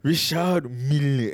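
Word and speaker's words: Richard Mille: He said it's Richard Mill.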